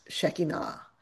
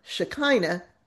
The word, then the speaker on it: Shekinah: In Hebrew, the word would be pronounced Shekinah, but I'll use the more familiar Shekinah pronunciation because that is how it is pronounced and understood in many Christian circles to this very day.